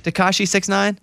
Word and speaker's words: Tekashi 69: Tekashi 69? – Yeah.